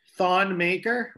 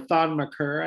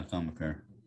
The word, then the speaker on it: Thon Maker: Thon Maker or Thon McCur, I think is the way they pronounce it. – Yeah, Thon McCur. – Thon McCur.